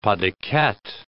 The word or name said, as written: pas de quatre